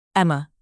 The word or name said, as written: emma